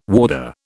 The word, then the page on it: warder: British English